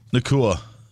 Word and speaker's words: Nacua: How do you say Puka's last name? – Nacua.